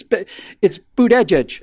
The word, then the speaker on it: Buttigieg: It's Buttigieg.